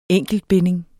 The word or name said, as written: enkeltbinding